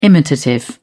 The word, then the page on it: imitative: British English